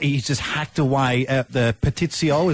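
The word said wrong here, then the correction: pastitsio: Is that how you say it? – Pastizio.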